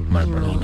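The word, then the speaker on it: Marlboro: Más ignorada que la R de Malboro. – Oye, sí, es que una R es que suena. – No se pronuncia. – ¿No se pronuncia? – Malboro. Nadie dice Malboro.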